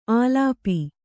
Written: ಆಲಾಪಿ

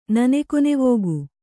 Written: ನನೆಕೊನೆವೋಗು

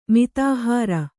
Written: ಮಿತಾಹಾರ

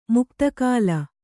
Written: ಮುಕ್ತಿಕಲ್ಲು

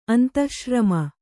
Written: ಅಂತಃಶ್ರಮ

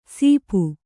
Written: ಸೀಪು

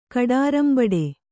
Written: ಕಡಾರಂಬಡೆ

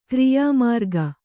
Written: ಕ್ರಿಯಾಮಾರ್ಗ

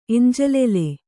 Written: ಎಂಜಲೆಲೆ

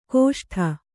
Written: ಗೋಷ್ಠ

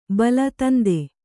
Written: ಬೈಲ

